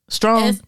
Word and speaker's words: Strawn: Strong. S-T-R-A-W-N. – Oh yeah, Strong. Yeah, S-T-R.